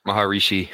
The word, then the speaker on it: Maharishi: Maharishi. – Maharishi.